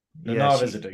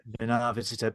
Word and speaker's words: Nana Visitor: And obviously, Nana Visitor, wow. Or how do you pronounce it? Is it Nana or is it Nana Visitor. – Yeah. Nana Visitor.